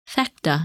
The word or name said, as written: Phecda